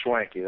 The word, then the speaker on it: Schwanke: Now let's talk about Todd Schwanke. Did I pronounce that right? – It's Schwanke, that's correct.